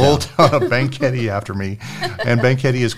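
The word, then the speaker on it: Banquete: How do you say that? – It's called Banquetti. – Banquetti.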